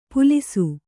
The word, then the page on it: ಪುಲಿಸು: pulisu; Kannada